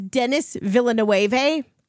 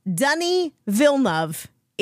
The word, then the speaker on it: Denis Villeneuve: I thought it was Denis Villeneuve. No, it's Denis Villeneuve.